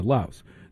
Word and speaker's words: Laos: Yeah, Laos.